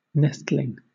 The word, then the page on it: nestling: British English